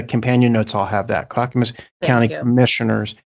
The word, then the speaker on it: Clackamas: Is it Clackamas? How do you say that? County? – Clackamas. – Mm-hmm. You're correct. – Clackamas County officials were laughing about what's going on.